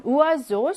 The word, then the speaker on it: oiseau: 2번은 il y a un oiseau sur l'arbre. 나무 위에 새가 있다 라고 했는데 oiseau에서 이거는 모음과 모음 중간에 s가 있기 때문에 얘는 즈 발음이 나고 있어요.